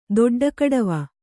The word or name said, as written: ದೊಡ್ಡಕಡವ